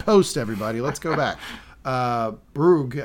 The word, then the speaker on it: brogue: Amalia starts off by imitating Penance's Bruges accent. Bruges is the right word, right? I'm pronouncing that right. That's a Irish, Scottish Bruges. That's what Brogue. – She's got. Brogue. There you go.